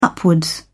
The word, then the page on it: upwards: British English